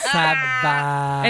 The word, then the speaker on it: Saavedra: Saavedra. – And it has two A's. So it's like, how do you pronounce a name that has two A's next to each other? – Oh.